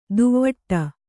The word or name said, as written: ದುವ್ವಟ್ಟ